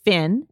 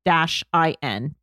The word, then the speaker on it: PHNN: It's called Finn, and it's spelled P-H-N-N. Finn, but you pronounce it Finn.